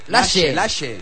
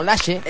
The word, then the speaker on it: Laxe: Pues bien, le podía decir a los de Telecinco del Telediario que no se dice Laxe, que se dice Laxe. – Ah, Laxe. ¿Cómo se dice? – Lache. Como si fuera she's en inglés, la SH inglesa.